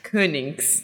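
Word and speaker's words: Koninckx: It's pronounced Konings. – Konings. – Konings, yeah. And even people here can pronounce it, but they can't write it because it's Flemish. – Yeah. – So at the end you have N-C-K-X, which is like, sounds made up.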